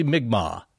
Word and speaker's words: Mi'kmaq: you guys are close, but it's actually Mi'kmaq. So, and I think I was putting a weird cue on it. – These are the native Mi'kmaq people of Nova Scotia who lived, were indigenous to the area where the Oak Island story takes place.